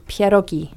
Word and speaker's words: pierogi: And it's a dumpling and it's called pierogi. – Mm. – You spell it pierogi, P-I-E-R-O-G-I.